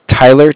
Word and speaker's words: Tyler: My name is Tyler, T-Y-L-E-R. – Tyler? – Correct. And I'm calling with Cardiff. It's spelled C-A-R-D-I-F-F.